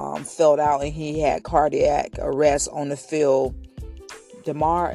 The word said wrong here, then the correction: Damar: is that his name? Did I spell his name? Say his name? It's DaMar. I know him, but I didn't say his name right.